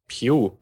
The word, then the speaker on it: Pugh: P-U-G-H. Puh? William Puh? – Pew, würde ich jetzt sagen. Aber das klingt dann wieder zu sehr nach Pew, ich weiß nicht. Also ich würde ihn jetzt Pew aussprechen. P-U-G-H, ja.